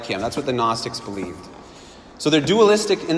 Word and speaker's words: Gnostics: One group I'll tell you about is the Gnostics. If you've ever heard of the Gnostics, it's spelled with a G at the front.